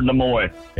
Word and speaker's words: Nimoy: It'd be Leonard Nimoy. – It is the great Leonard. I've never heard it pronounced that way. I've always said Nimoy, but I'll take Nimoy.